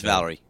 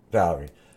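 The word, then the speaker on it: Valory: Ross Valerie. – Valerie.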